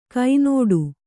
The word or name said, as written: ಗಿಣ್ಣಿಡು